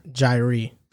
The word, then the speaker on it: Jair: Jair. Jair. – Who told you that? – Oh, no, hold up. Because it's Zaire with just a J. It's Zaire with a J.